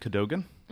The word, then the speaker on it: Cadogan: Well, I pronounce it Cadogan. – Cadogan. Apparently that indicates how posh you are.